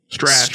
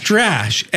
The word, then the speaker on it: Strash: Infinity Strash. – Strash. Strash. Like trash. – Yeah, but with an S. They have trash in their name, but they put an S in front of it.